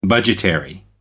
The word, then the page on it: budgetary: American English